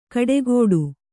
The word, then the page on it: ಕಡೆಗೋಡು: kaḍegōḍu